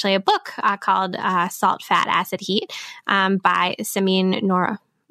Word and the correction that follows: Samin Nosrat: Wow, Samin Nosrat. I totally said that wrong.